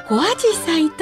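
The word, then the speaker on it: 小紫陽花: 濃い紫陽花と書いて小アジサイと読みます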